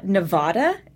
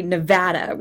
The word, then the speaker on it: Nevada: It's not Nevada, as Kerry had said, you know, just before the time I was taking this trip. You know, it's actually Nevada with, you know, that short A, like as flat an intonation as possible.